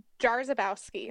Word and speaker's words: Jarzabkowski: Jarzabkowski.